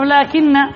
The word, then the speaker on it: لكن: ها خمسة أحرف، لكن، لكن عندنا لام، عندنا ألف لا تنطق لا تكتب، اثنان، وعندنا كاف ثلاثة، وعندنا النون مشددة بحرفين خمسة، خمسة. هذه الألف لا تكتب بعد اللام، تراجع في الرسم، في الرسم. علم ماذا؟ الرسم، علم الإملاء، علم الإملاء. بعض الحروف ماذا؟ تنطق ولا تكتب.